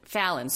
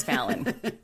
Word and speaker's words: Fallon: Okay, so cut to Halloween party at Phelan's house. Now, before this happens, we just have to make mention. Fallon. I have it written out phonetically the wrong way in my notes. It's Fallon.